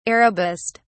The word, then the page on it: arabist: British English